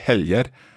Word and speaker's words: helg: So if we say just a singular, helg, so it's a J sound.